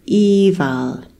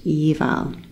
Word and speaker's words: Aoibheall: That's our first Irish word, which is eval, which is actually spelt A-O-I-B-H-E-A-L-L, but it's pronounced eval.